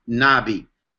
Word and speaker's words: nabi: One of the most common words is the word Nabi, and you spell that N-A-B-I, Nabi.